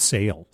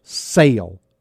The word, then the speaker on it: Soehl: Do you think it's Sell or Soul? – I think it's Sale. – Sale. Sale. It's S-O-E-H-L, right? S-O-E-H-L. Yeah. Kurt Sale. Kurt Sale.